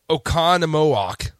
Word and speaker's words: Oconomowoc: Oh my gosh, Oconomowoc, Wisconsin.